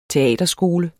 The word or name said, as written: teaterskole